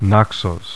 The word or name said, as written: Naxos